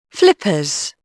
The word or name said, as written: flippers